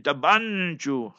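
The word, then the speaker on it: Thaba Nchu: That's how they pronounce it. Tabanchu.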